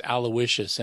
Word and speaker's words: Aloysius: In fact, I didn't even know how to say his first name. It's Aloysius, and I had to find out.